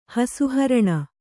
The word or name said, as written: ಹಸುಹರಣ